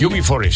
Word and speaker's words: UB40: Que originalmente fue escrita, interpretada y grabada por el cantautor estadounidense Neil Diamond en 1967, pero lo que estamos escuchando es la versión que hizo posteriormente la banda británica V40, no sé si pronunciar así. – UB40, sí, señor.